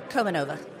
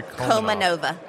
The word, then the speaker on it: Komanova: Say your last name for me, Lorie. – Komanova. – Say it again. – Lorie Komanova.